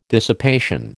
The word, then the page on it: dissipation: American English